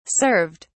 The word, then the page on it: served: British English